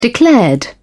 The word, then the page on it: declared: British English